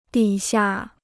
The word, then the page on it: 底下: dǐ xia